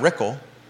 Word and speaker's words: Rickle: Brad Rickle, rhymes with pickle, $10,300.